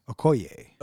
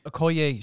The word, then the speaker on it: Okoye: Okoye, is that how you pronounce her name? – Okoye. – Okoye, she hits the ground.